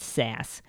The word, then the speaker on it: SAS: SAS. According to their website, SAS, pronounced S-A-S-S, even though it's spelled S-A-S, once stood for Statistical Analysis System, but now the name of the company is just SAS.